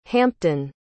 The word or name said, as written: hampton